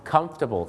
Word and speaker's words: comfortable: หลายคนก็อาจจะออกเสียงว่า comfortable ซึ่งก็มีฝรั่งบางคนนะครับออกเสียงว่า comfortable นะครับแต่จริงๆแล้วนะครับถ้าถูกต้องเลยนะฮะเขาจะออกเสียงว่า comfortable ครับ comfortable ไม่ใช่ comfortable นะครับ